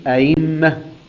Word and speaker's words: أئمة: تنطق بالتسهيل أئمة، أئمة بالتسهيل وليست بالياء، أئمة.